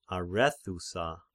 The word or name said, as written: Arethusa